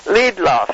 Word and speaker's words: Liedloff: May I say Liedloff? – Yes, please say your name again. – Liedloff. L-I-E-D-L-O-F-F, yeah.